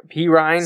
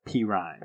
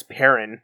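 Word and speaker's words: Perine: P. Ryan. Samaje P. Ryan. – I thought it was Perrin. That's how I've been saying it. That's why when you said P. Ryan, I was like, I'm pretty sure that's it.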